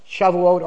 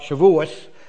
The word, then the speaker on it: Shavuot: Shavuot, or as I used to call it when I was growing up, Shavuos. We had a little different pronunciation from Eastern European Jews from the Middle. Today, the right way to say it is the way they say it in Israel, okay? The Israeli pronunciation.